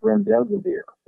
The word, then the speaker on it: remdesivir: Is that correct pronunciation of that, doctor, the medication remdesivir? – That's correct, remdesivir.